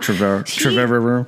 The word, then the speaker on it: Trevorrow: Trevorrow.